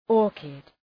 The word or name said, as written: orchid